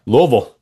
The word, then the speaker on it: Louisville: I'm just proud of you for pronouncing Louisville correctly. That's good stuff. – Louisville, that's right.